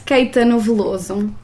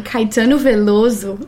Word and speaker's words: Caetano Veloso: Can you do a beautiful Portuguese pronunciation. – Yeah. So that's Caetano Veloso. – Okay. – Oh. I can even do a Brazilian one, Caetano Veloso.